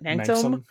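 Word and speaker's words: manxome: How would you pronounce it? Manxum. Yeah.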